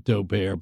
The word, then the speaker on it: Daubert: D-A-U-B-E-R-T. Some people pronounce it Daubert, but they're just snobs. – Daubert it is.